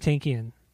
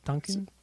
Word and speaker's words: Tankian: Well, to really throw back to people that to somebody who people don't really remember, Serge Tankian. Tankian? How do you pronounce his name? How do you pronounce this guy's name? – Tankian was fine. Tankian? I always just said Tonkin because Tonkin.